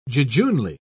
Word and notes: jejunely: British English

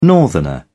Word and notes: northerner: British English

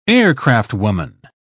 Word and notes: aircraftwoman: British English

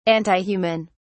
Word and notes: antihuman: British English

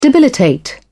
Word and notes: debilitate: British English

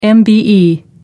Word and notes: mbe: American English

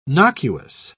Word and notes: nocuous: British English

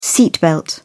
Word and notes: seat belt: British English

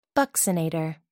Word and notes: buccinator: American English

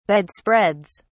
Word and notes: bedspreads: British English

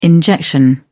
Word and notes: injection: British English

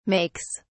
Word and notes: makes: British English

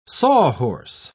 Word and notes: sawhorse: British English

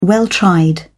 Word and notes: well tried: British English

